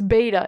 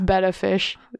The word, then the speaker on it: betta: And I know people are going to be like, it's beta. It's not. It's betta fish. It's beta what? is just a mispronunciation. It's called the betta fish.